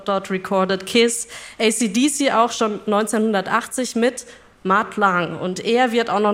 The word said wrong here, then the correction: Mutt Lange: Spricht man ihn, ja, glaube ich, aus, ne? – Also er hat deutsche Vorfahren. Wir würden sagen Mutt Lange. – Lange, aber ja.